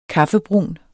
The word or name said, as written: kaffebrun